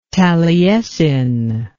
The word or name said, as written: taliesin